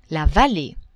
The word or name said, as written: vallee